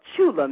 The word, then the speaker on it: Tchula: I am a native of Chula, Mississippi. That's spelled T, silent T, C-H-U-L-A.